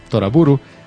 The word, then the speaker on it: Toraburu: Se pronuncia Toraburu del inglés Trouble.